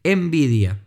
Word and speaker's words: Nvidia: que es la de Nvidia. Para los que no conocen, se escribe N, B corta, I, D, I, A. Nvidia, sin la E, digamos, ¿no?